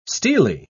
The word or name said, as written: steely